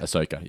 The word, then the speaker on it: Ahsoka: because I haven't watched Clone Wars or Rebels, as I pointed out, but in the past, but just the very presence of Ahsoka in this... I hope I'm pronouncing that correctly. Ahsoka? Ahsoka? – That's how I ever said it. I've always said it, Ahsoka, yeah.